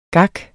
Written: gak

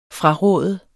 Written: fraråde

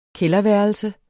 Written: kælderværelse

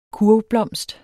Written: kurvblomst

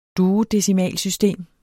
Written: duodecimalsystem

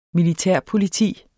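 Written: militærpoliti

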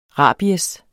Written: rabies

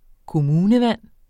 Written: kommunevand

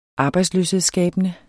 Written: arbejdsløshedsskabende

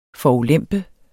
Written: forulempe

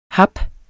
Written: hap

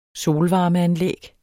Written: solvarmeanlæg

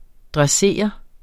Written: dressere

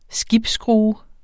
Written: skibsskrue

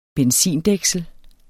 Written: benzindæksel

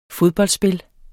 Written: fodboldspil

